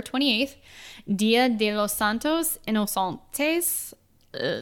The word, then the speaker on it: Inocentes: they would say Inocentes. – Inocentes. Dia de los Santos Inocentes.